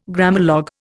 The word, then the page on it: Grammalogue: Indian